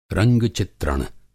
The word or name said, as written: रंग-चित्रण